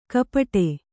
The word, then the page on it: ಗುಪ್ಪಟ್ಟೆ: Kannada